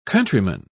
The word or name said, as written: countryman